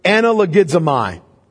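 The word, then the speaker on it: analogizomai (ἀναλογίζομαι): That word that's translated as consider is a Greek word, analogizomai. I'm going to spell it because somebody was very thankful on Wednesday night that I spelled the last Greek word that I gave you. Analogizomai, A-N-A-L-O-G. I-Z-O-M-A-I. Analogizomai.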